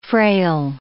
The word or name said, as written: frail